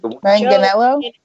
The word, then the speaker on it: Manganiello: You uh, mentioned something about Joe Mangiello. How do you say it? Joe Mangiello. Uh, Manganiello. Joe, yeah, Manganiello.